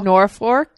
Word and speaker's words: Norfolk: Norfolk.